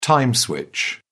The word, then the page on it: time switch: British English